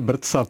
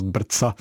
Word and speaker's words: BRCA: Například u těch genů brca 1, já jsem moc rád, že říkáte brca, protože nikdo nevěděl, jak eh, ty geny máme vyslovovat a my jsme v laboratoři eh, docenta Polreicha, který by je jeden z nestorů toho genetického vyšetřování na dnešním ústavu eh, biochemie, experimentální onkologie první lékařské fakulty, tak razili právě to tu výslovnost brca, která je taková specificky česká.